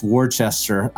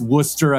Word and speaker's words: Worcester: And if you're looking at it, you might think it's pronounced Worcester. I did that once when I was interviewing for a university position up here. They corrected me and I've never done it again. So it looks like Worcester. It's Worcester. Imagine W-U-H-S-T-A-H.